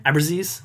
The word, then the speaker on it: Abruzzese: How do you say his last name? – Abruzzese. – I think I say Abruzzese.